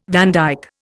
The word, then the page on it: vandyke: Indian